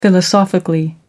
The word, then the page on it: philosophically: American English